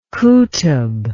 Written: qutb

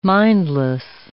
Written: mindless

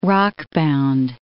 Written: rockbound

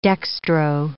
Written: dextro

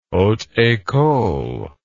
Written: haute ecole

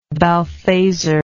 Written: balthazar

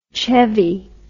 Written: Chevy

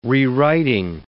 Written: rewriting

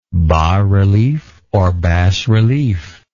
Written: bas relief

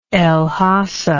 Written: el hasa

